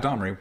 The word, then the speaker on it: Domri: Domini Raid would be proud. – Yeah. – I probably butchered the pronunciation of that, but you understand. – Yeah, Domri. – Okay. – No, Domri, cool.